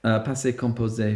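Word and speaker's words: passé composé: Passé, composé. How do you say it? – Passé, composé, futur conditionnel.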